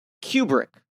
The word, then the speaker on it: Kubrick: Kubrick.